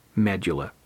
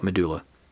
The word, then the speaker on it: medulla: Well, in this case, the tumor was centered on the medulla or medulla. So there are different ways to pronounce that, and I pronounce it differently just randomly. But today we'll call it medulla.